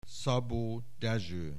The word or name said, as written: Szabó, Dezső